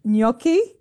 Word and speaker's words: gnocchi: I would say gnocchi.